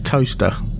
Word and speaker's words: Towcester: One of my favorite ones over here is Toaster, which is spelt T-O-W-C-E-S-T-E-R.